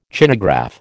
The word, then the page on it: Chinagraph: American English